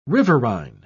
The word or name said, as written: riverine